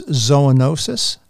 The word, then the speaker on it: zoonosis: And the technical biological term is zoonosis. Am I pronouncing that correctly? – That's right. That's right.